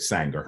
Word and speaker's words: Sanger: Well, I encourage people to do their own research on Margaret Sanger. Her name is spelled just like danger, but with an S.